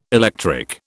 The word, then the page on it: electric: British English